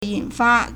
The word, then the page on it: 引发: yǐnfā